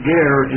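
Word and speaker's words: ger (גֵּר): It's the Hebrew word ger, pronounced in English as we would pronounce G-A-R-E.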